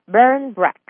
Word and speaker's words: Barmbrack: and it's called Barn Breck. That's right. Now, it's spelled B-A-R-M-B-R-A-C-K.